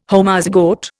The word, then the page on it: Homozygote: Indian